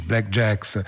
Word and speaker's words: BLKJKS: Si scrive BLKJKS e come è un po' moda negli ultimi anni si eliminano eh, le vocali, ma insomma mm, va letto in questo modo.